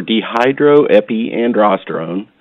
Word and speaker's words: dehydroepiandrosterone: DHEA, short for dehydroepiandrosterone.